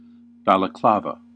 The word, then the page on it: balaclava: American English